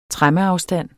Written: tremmeafstand

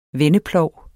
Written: vendeplov

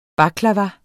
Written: baklava

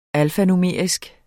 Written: alfanumerisk

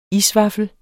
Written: isvaffel

